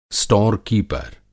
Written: स्टोर-कीपर

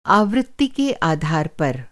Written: आवृत्ति

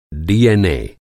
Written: डीएनए

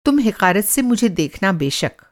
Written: हिक़ारत

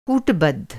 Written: कूटबद्ध

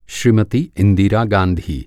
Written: श्रीमती

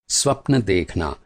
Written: स्वप्न

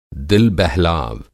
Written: दिल-बहलाव

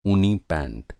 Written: पैंट

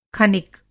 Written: खनिक

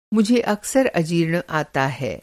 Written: अजीर्ण

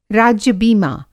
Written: राज्य-बीमा

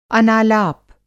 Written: अनालाप

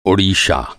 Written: ओडिशा

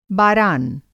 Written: बारान

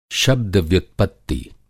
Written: शब्द-व्युत्पत्ति

